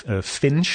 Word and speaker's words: FNNCH: Er was er eentje, die is redelijk bekend in Amerika, die heet Finch, zo spreek je het uit, F-N-N-C-H.